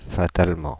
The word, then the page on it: fatalement: Paris; [fa.tal.mɑ̃]